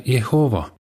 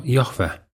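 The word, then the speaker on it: Jehoova: Eri keeltes hääldatakse seda nime ise moodi. Eesti keeles öeldakse Jehova, vahel ka Jahve.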